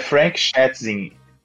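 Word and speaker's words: Schätzing: É, é Frank Schatzing, S-C-H-A-T-Z-I-N-G.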